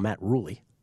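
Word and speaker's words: Rhule: Matt Rule, or as Kelly likes to call him, Matt Ruley, because apparently that's what he told him, how it's pronounced to his face.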